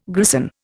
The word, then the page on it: grison: Indian